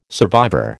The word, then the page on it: survivor: American English